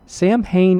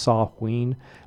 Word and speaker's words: Samhain: 1955, Glenn Danzig, singer-songwriter, record producer for the Misfits, Danzig, and Sam Hain. I don't know how they pronounce it. The actual holiday in Ireland is pronounced Samhain, but I think here in the States we pronounce it Samhain.